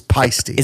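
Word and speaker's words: Paiste: We've been corrected by a listener on our pronunciation of it's Pisty. – Is it Pisty? – Okay. So they said, make sure next time this comes up, it's Pisty cymbals.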